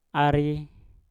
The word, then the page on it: ari: mpur